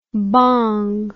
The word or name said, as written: Bhong